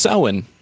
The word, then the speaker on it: Samhain: which is spelled Samhain, and you go to Wikipedia, you'll see that it's pronounced Samhain.